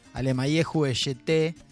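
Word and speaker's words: Alemayehu Eshete: Vamos a ir al encuentro de un cantante que es conocido como el Elvis etíope, Alemayehu Eyete, sería la forma de pronunciarlo, es muy difícil su nombre, en amárico, que es el idioma original de Etiopía.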